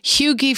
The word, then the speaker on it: hygge: actually, like there is, like we're, we're all pronouncing it wrong, but it's H Y G G E and it's something like hugie and it's a Danish word, and it just means being cozy.